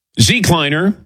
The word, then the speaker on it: Z-cliner: Z-cliner rhymes with recliner, but it's so much more than that.